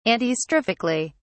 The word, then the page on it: antistrophically: British English